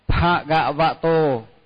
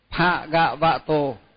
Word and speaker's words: Bhagavato: Bhagavato. Bhagavato. Chữ chữ pha là nhớ là dòng môi, tức là phải đọc dòng môi. Pha gạ vạ tô,